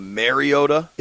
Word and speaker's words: Mariota: I think where people were really mispronouncing it was the saying kind of Mariota. It's Mariota. – Mariota. – And I think maybe Collinsworth was a little. – He was overly exaggerating the end? – The end. I think it's more. I don't know. – Mariota. – I'm not good at like the phonetic stuff. I think people are pronouncing it with like a soft A and it's more of a hard A. Like, think about Super Mario Brothers.